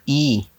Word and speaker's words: Yves: This guy's name, Yves, if that's how you say his name. – E? – Or Ives Bihar. – I think Bihar. – They say E. – Yves? – Yves Bihar? – Yeah, Yves Bihar.